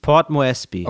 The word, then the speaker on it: Port Moresby: Wie spricht man Port Moresby aus? Ist sie wirklich die erste Port? – Mhm. Das ist klar, wegen Hafen? – Moresby, Port Moresby.